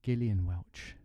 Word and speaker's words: Gillian Welch: We have, let me say her name right, Gillian Welch. – Yes. – I once said Gillian and got told off saying it that way.